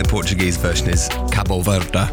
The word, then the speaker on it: Cabo Verde: I think the Portuguese pronunciation would be Cabo Verde.